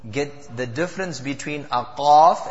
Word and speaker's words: qaf: Last week we had discussed the difference between the qaf and the kaf. So kaf was easy recited, free-flowing kaf as compared to qaf, and that would is pronounced with a full mouth.